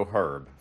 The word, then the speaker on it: Herb: It's H-E-R-B. – Yes. – Do they pronounce the H? – Yes. Yes. – So they go Herb for Herb. Yes. Okay.